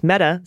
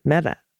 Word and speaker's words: Mehta: Mehta, slight emphasis on the meh. And the tuh is pronounced like the Spanish, with the tongue at the tip of the teeth. Oh, Mehta.